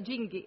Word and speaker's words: Jing: Isso, é o Jing.